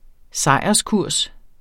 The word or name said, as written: sejrskurs